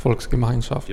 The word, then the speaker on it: Volksgemeinschaft: Man hade ju ett projekt, Volksgemeinschaft.